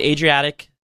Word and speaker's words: Adriatic: So, the Adriatic Sea, is that how you pronounce it? – Yep. Mm-hmm.